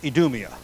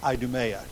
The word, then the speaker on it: Idumea: In the New Testament, Edom is called Edomia or Idumea, depending on which pronunciation you prefer.